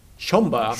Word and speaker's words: Schomburg: hoe spreek je dat eigenlijk uit? Schomburg? Schomburg. Schomburg. En Aaron eh, Royal.